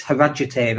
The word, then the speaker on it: Harrogate: And it's not Harrogate, it's Harajite. That's how they pronounce it.